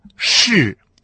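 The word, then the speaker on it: ши (是): Так вот, эта связка в китайском слова «ши». С каким тоном она произносится? Совершенно верно. Связка «ши» произносится с четвертым тоном.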